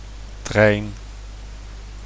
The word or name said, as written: trein